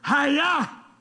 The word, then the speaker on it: Chayah: The pronunciation of this word is hiya.